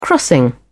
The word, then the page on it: crossing: British English